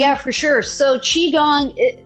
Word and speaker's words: Qigong: Is it Qigong? – Qigong, yes.